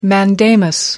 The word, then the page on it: mandamus: American English